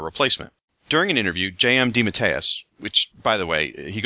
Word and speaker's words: DeMatteis: And yes, that is apparently how you pronounce his last name. I asked him on Twitter. It's DeMatteis.